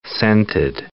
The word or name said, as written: scented